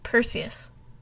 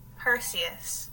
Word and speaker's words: Perseus: Well, how do I say it again? – Perseus. – Perseus. Okay.